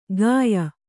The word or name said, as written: ಗಾಯ